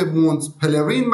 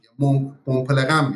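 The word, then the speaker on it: Mont Pèlerin: با تلفظ انگلیسی به مونت پلرین معروفه یا تلفظ فرانسش قاعدتا نازال میشه مون پلغم میشه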